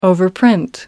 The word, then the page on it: overprint: American English